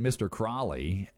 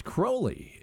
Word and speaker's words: Crowley: You know, it's kind of funny because in that song, Ozzy pronounces it Mr. Crawley. And with that song being about Alistair, it's pronounced Crowley in reality.